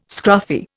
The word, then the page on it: scruffy: Indian